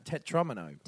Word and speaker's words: tetromino: Because I've seen that written down and I've always thought of it as Tetromino. – No, Tetromino. – Tetromino. – Tetromino. – Like Domino.